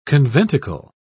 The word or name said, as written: conventicle